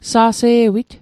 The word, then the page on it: sa'se'wit: Mi'gmaq